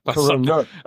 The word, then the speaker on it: Coroner: Coroner.